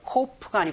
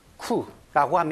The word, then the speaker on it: coup: 영어로는 coup가 아니고 coup라고 합니다. P가 발음되지 않는다는 것도 알아두시고요.